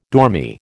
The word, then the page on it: dormy: American English